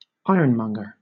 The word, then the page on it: ironmonger: British English